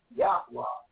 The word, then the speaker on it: Yahweh: We're doing a series called The Due Order of YHWH, proper pronunciation, Yahweh, to his people Israel.